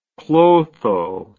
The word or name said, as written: Clotho